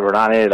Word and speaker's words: Varane: Yeah, Varane, how you pronounce it, Varane.